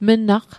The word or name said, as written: mnaq